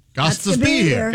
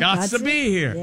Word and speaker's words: Gostisbehere: No, S-H-A-Y-N-E. Okay. – Oh. – His last name, Guts to be here. G-O-S-T-I-S-B-E-H-E-R-E. How do you say that? – Do it. Spell it one more time. – G-O-S-T-I-S-B-E-H-E-R-E. – Got to be here. – Got to be here. – Yep. Got to be here.